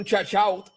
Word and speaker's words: shout-out: Oye, dándole un shout-out, un shout-up. ¿Cómo se dice un shout-up? A Denis Rivera. – Shout-out.